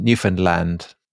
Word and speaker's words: Newfoundland: You've got to understand Newfoundland is how you remember it. – Newfoundland. – Newfoundland. Understand Newfoundland. – Oh, right. So we're doing it totally wrong. – Yeah. So it's Newfoundland.